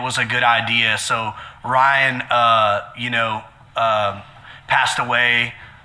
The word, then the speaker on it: Rhien: And it's super, it's pretty, we don't get this a lot, but there was a guy, his name was Ryan, and he spelled it R-H-I-E-N, and people ask us that all the time, but we pronounce it like Orion, like Orion's belt, right.